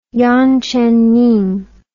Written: yang zhenning

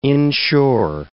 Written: inshore

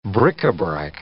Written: bric-a-brac

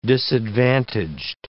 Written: disadvantaged

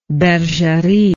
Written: bergerie

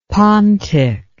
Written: pontic

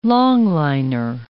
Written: long-liner

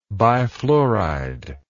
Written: bifluoride

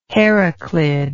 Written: Heraclid

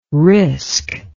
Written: risc

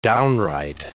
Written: downright

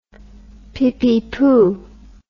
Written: pippypoo

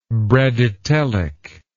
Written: bradytelic

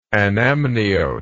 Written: anamniote

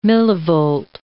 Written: millivolt

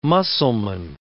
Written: Mussulmen

